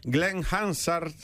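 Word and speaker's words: Glen Hansard: Glenn Hansard...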